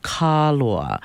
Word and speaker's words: Kanaloa: And so these moon phases are named for Kanaloa, but we pronounce it Kaloa.